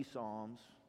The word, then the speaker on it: Psalms: If you're new to the Bible, it looks like P Psalms, but the P is silent. The book of Psalms 133 is where we're going to be.